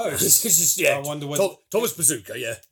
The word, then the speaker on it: Bezucha: Yeah, his surname is spelled B-E-Z-U-C-H-A, but it's pronounced Bezucha.